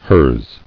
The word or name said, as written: Hers